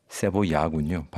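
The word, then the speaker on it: cebolla: 1930년대부터 50년대까지의 그 멕시코 시티 중심으로 한창이었던 볼레로, 카바레, 어구스틴 라라의 곡들 뭐 이런 것들을 이야기하면서도 자신의 나라 칠레의 그 무시카 카베 세볼라, 무시카 세볼라, 세보야군요. 발음이 세보야 같은 이런 심파 발라드 그리고 엔카.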